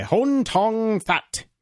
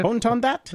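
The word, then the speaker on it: Hoan Ton-That: And this is about a company called Clearview AI, run by Hon Tong Thot. I don't know how you pronounce that guy's name. – That's pretty close, actually. Yeah. – Hon Tong Thot. – I think you almost got that.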